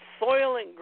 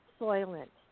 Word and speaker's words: Soylent: Well, actually, people don't know because there's a company out there that has made a protein drink, and they call it Soylent. It's like, what? Are you kidding me? They spell it different. They don't have the Y in it. They spell it different, but it's pronounced the same.